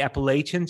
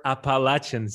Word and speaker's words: Appalachian: And believe it or not, but even though most Americans would really say Appalachian, just as you pronounce that, they would actually prefer Appalachian State University.